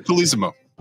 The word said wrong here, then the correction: Colosimo: You say the name. Peter the Falcon. – No, Peter. No. Peter Colosimo. – Colosimo.